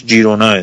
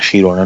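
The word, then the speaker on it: Girona: و این تلفظش جیرونا یعنی تو انگلیسی حداقل میگن جیرونا یعنی خیرونا نیست که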